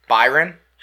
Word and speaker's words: Bayern: Byron.